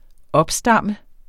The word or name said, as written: opstamme